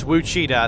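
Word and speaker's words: Ouachita: And it's spelled with an O. It looks like Ouchita, but it's Wichita.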